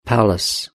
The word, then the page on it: palace: British English